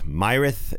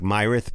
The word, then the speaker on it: Myrath: The band, M-Y-R-A-T-H, Myrath. Myrath. It looks like Myrath, but I believe they say Myrath.